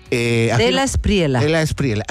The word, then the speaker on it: De la Espriela: Despriela. ¿Así se pronuncia? ¿Despriela? – De la Espriela.